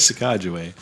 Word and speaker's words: Sacagawea: You have like 30 different pronunciations. – No, it's always Sacagawea.